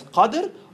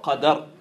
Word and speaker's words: Qadr: You can pronounce it as Qadr or Qadr, with a fatha or with a sukun.